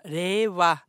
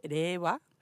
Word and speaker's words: Reiwa: Det heter altså da rewa. Og det skrives R-E-I-W-A. Så hvis du skal si det med norsk uttale, så må det jo nesten bli Reiva.